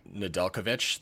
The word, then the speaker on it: Nedeljkovic: Nedeljkovic, thank you, is playing out of his mind.